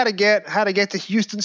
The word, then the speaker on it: Houston: And you mentioned Houston Street there, which in your really, really helpful notes that you sent is spelt in such a way that you would imagine it was pronounced Houston, as in Whitney Houston or Houston, Texas. But you very kindly showed us phonetically that we should pronounce it Houston, so that if we're asking for directions, we wouldn't get a sarcastic answer from any New Yorkers.